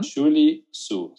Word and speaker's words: Julie Zhuo: Julie Su. Se escribe J-U-L-I-E-Z-H-U-O. – Ok.